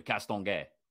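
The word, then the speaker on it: Castonguay: You got to put on some French when you say her name properly. Castlin-Gay. – Yeah, you do a little bit.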